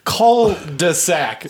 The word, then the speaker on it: cul-de-sac: How do you say it? – Cul-de-sac. There's no T. – How do you spell it? – C-U-L-D-E-S-A-C. Cul-de-sac.